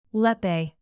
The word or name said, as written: lepe